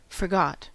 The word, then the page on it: forgot: American English